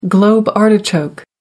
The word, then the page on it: globe artichoke: American English